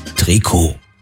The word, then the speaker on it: Trikot: Jahrhundert setzte sich der Begriff für eng anliegende Sportkleidung durch, weil diese eben aus dem dehnbaren Strickstoff hergestellt wurde und weil im Französischen in der Regel die letzte Silbe betont wird, heißt es richtig Trikot.